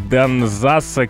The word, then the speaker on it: Данзас: Там молодые актеры зажигают залы, играючи создают наброски портретов Горчакова, Дельвига, Данзаса, Кюхельбекера и француза Пушкина. Я не помню, все ли ударения правильно поставил. – Дельвик, Данзас, да, вроде все правильно.